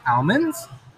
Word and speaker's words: almond: Almond, not owl.